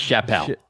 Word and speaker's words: Chappelle: Another Chappelle. His name's pronounced Chappelle.